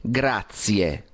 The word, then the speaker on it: grazie: Otra cosa, quando dices todo bien e luego hai bene, grazie, grazie, no, no, non si dice así, si dice grazie con z, grazie.